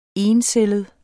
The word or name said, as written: encellet